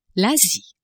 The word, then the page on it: Asie: French